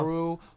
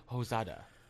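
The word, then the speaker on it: Mamoru Hosoda: Mamoru Hosoda. – Hosoda.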